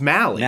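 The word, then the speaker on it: Mahle: Yeah, it is Molly, right? – Yeah, it's Molly. No, it's Mally. – Mally? – Okay, I just looked this up. Pronunciation rhymes with rally.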